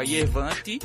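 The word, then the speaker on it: Yervant: Só que ela se escreve de forma frescalhada, assim, com a Yervante, né, tipo, da Y, Yervan, com T mudo, no final, Kisajikian.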